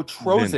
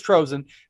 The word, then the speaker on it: Trozen: No, short I. – Oh, Trozen. Vin. Got it. Trozen. Sorry. – It's not Tolzine, all right? You damn Wisconsin people. – So I draw that from having people in my life when I was growing up whose last name is without the T, Rosine, R-O-S-I-N. So that's where that's coming from. But okay, we're on to a correct pronunciation.